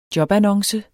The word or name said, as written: jobannonce